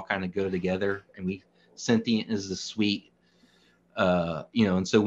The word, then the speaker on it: Cntient: So looking at your website, you have, so is it called Sentient? C-N-T-I-E-N-T? Is that how it's pronounced? – Yes, that's correct.